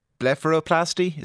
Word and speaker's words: blepharoplasty: Okay, so the surgery itself then, this blepharoplasty, is that how you pronounce it? – Mm-hmm.